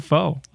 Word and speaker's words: Pho: P-H-O. – Pho. – It's like you're saying the F word, but you eject on the C-K. – Yes. – But I like calling it pho.